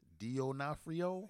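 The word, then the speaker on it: D'Onofrio: D Onofrio? – Yes.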